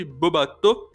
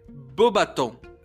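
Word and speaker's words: Beauxbatons: O Lauro Bittencourt que é um cara que eu troco muita ideia com ele no Instagram, ele me ajudou a corrigir a minha pronúncia sobre a escola de Bobatô. Segundo ele, o correto é Bobaton.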